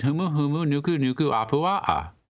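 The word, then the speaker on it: Humuhumunukunukuapua'a: It's it's all repeated words. Humu humu nuku nuku apua'a.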